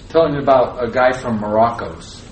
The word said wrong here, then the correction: Morocco: Is that right, Morocco's? Am I saying that right? Morocco.